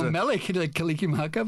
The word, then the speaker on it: Mele Kalikimaka: Something from Willy Kalikimaka. Did I say that right? – Well, I don't know. I'm not Hawaiian, but, okay, but I know Mele Kalikimaka, but yeah.